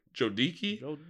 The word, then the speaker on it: Jodicke: And Jonas Jodecki... Jodicke? – Jodicke. – Jonas, J-O-D-I-C-K-E. I'm sorry for mispronouncing that. I think it's Jodicke.